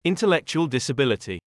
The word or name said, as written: intellectual disability